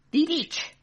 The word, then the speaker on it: Dietsch: We got the recipe from a book called Shrubs, An Old-Fashioned Drink for Modern Times by Michael Dietscht. D-I-E-T-S-C-H. Is that how you pronounce that? – Dietscht.